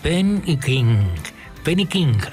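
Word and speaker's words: Ben E. King: Hoy habría estado de cumpleaños Benny King, así lo pronunciaban en la época.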